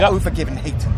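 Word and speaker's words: Övergivenheten: Let me try and pronounce this right, because Overgiven Hayton or Overgiven Hayton. – Yeah. – Is that right? – Yeah, well done.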